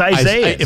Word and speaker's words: Isaias: Well, Isaias, or however you pronounce it, is not Isaias. It's Isaias.